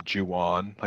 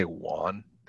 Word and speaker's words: Juan: And And then how about when Juan first arrives and they're calling him Juwan? Like, they don't know how to pronounce Juan. It's like, how do you, even in 1995, it's not like 1840. You live in Texas, you don't know how to say Juan.